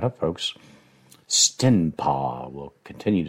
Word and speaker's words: Stenpa: That's spelled S-T-E-N-P-A, Stenpa.